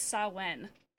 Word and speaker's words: Samhain: But I'm pretty sure that's not how you pronounce that word. Pretty sure it's Samhain.